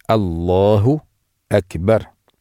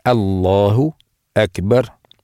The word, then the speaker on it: Аллаху Акбар: То есть говорит «Аллаху Акбар». «Аллаху Акбар». «Аллаху Акбар». Я трижды повторял, чтобы вы слышали, как надо произнести.